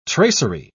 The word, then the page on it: tracery: British English